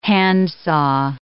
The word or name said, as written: handsaw